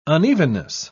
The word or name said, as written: unevenness